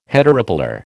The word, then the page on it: heteropolar: American English